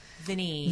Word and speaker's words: Vanille: And you've got, and for this event too, with all the restaurants that I mentioned earlier, in addition to Vanille, how'd you pronounce it there? – Vinny, like your knee.